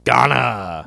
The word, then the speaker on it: Ghana: Uh, is it uh, Guinea? – Ghana. – Ghana, Ghana, Ghana. I mispronounced it. Yes. Ghana. Guinea is French Guinea. Yes. – Right? Yes. Yeah. Ghana, not Guinea.